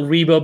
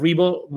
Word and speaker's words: Ribo: Yeah, the easiest way to find me, well, I mean, if you Google me, it's easy to find me on online, Angel Rebo, but I would say that the easiest social media outlet is LinkedIn, the professional network. If you go there and you look for Angel Rebo, my last name is spelled R-I-B-O for those that are going to listen to this instead of watching it.